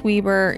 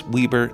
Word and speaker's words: Weber: And just a quick PSA for listeners, I'm from South Weber, and it's Weber, not Weber. Same for Weber State. You people in the East are fired for pronouncing this wrong all the time.